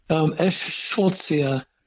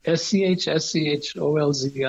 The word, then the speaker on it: Eschscholzia: whose genus I can't pronounce. – Eschscholzia californica. – Eschscholzia. – Yes. – Eschscholzia. – That's right. Eschscholzia.